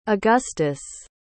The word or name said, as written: augustus